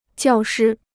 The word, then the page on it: 教师: jiào shī